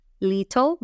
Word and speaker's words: little: Cuando decimos la palabra pequeño, no estamos diciendo little, ¿verdad? Sino que decimos, dilo rey. Little. Little.